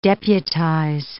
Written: deputize

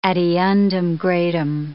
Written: ad eundem gradum

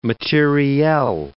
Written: materiel

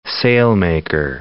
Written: sailmaker

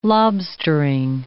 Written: lobstering

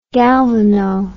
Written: galvano

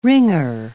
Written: wringer

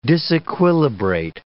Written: disequilibrate

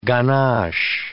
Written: ganache

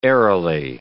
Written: airily

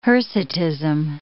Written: hirsutism

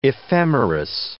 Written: ephemeris

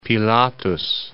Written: Pilatus

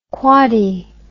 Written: quoddy

